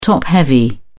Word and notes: top-heavy: British English

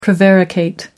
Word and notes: prevaricate: American English

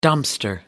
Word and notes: dumpster: American English

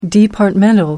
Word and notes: departmental: American English

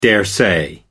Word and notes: daresay: American English

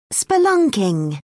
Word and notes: spelunking: British English

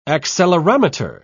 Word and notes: accelerometer: British English

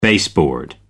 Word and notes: baseboard: American English

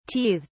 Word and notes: teethed: British English

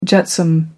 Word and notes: jetsam: British English

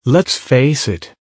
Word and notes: lets face it: American English

